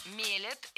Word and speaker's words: мелят: Уже много лет у нас в стране продаются вот эти бытовые кофемашины, которые сами мелят, делают эспрессо. – Мелят – это неверно. Правильно, мелят, мелит, мелим, мелиш. Ударение в этом слове всегда стоит за корнем, а корень остается безударным.